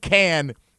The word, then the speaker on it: Cann: Now, he called him Cann. Brugler called him Cann. I checked with the PR staff. I was told Cann is the pronunciation. So I'm going with Cann for now.